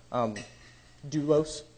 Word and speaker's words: doulos: Doulos, that's how you pronounce it, doulos.